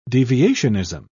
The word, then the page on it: deviationism: British English